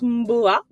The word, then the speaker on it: Mboa: En réalité, ça se prononce Mboa.